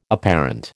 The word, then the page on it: apparent: American English